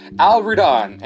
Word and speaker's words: Al-Rudhan: Al-Rudhan.